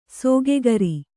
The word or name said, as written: ಸೋಗುಗಾರ